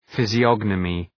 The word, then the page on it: physiognomy: ,fızı’ɒnəmı, ,fızı’ɒgnəmı